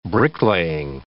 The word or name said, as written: bricklaying